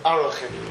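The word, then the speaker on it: Arachin (ערכין): So this is a good preparation of, in fact to accurately pronounce it would be Arachin. But nonetheless, people say Erechin, so we'll stick with that.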